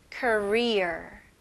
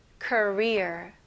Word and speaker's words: career: 한국 Korea하고 되게 비슷하죠? 자, 한국은 Korea 이렇게 끝부분이 중요합니다. 시작은 사실 거의 똑같아요. Korea 그리고 이거는 career. Okay. 이 R로 끝나는 단어들은 힘을 주시면서 R를 명확하게 발음해 주셔야 됩니다. Okay. 자, 해볼게요. career. career. 이거는 좀 끝부분을 이렇게 약간 게을르게 그냥 career 이렇게 하면 한국같이 들려져요.